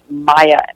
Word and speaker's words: Maija: My name is Maya, which is spelled M-A-I-J-A.